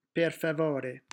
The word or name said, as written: per favore